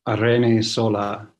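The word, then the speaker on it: Irene Solà: So my Spanish housemate assures me that Irene Zola is my correct pronunciation.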